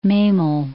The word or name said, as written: Memel